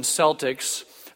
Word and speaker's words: Celtic: Did you know that in primitive Britain, the Celtic kings, and all you fans of the Boston Celtics, there was no soft C sound in that language, and so it would have been Celtic. Celtic kings sometimes would go to war with one another over the slightest perceived insult.